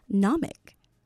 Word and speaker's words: gnomic: And this afternoon, your word we're looking at is gnomic. Gnomic. It is spelled G-N-O-M-I-C. G-N-O-M-I-C. Gnomic. Of course, the G is silent.